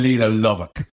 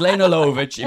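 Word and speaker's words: Lene Lovich: Oh, what do you mean, Lena Lovick? That's, you're reading that. It's pronounced Lena Lovitch.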